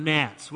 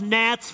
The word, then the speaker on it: gnats: and here you go with a silent G word for gnats. Well, it's in the Bible. Blood, frogs, gnats, flies, livestock, boils, hail, locusts, darkness, death of the firstborn.